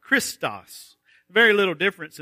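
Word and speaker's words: Christos (Χριστός): The first one has a long E sound, Christos. And it is a word that means good. It's the word that's found there for kindness. The second word is the word for Christ, Christos.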